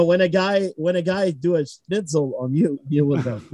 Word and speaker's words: Schnitzel: I think the most popular German food is schnitzel.